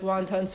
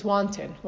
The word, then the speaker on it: wanton: We talk about the Zaydim, the wonton. I'm saying it wrong. My mother told me it's not wonton. She said like wonton soup. It's wonton. Wonton. Okay.